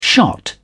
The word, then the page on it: shot: British English